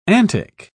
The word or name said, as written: antic